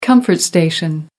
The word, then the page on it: comfort station: American English